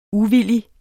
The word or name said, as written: uvillig